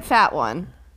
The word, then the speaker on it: Fatone: It's spelled fat one, and it's but it's called Fatone, and that's horrible.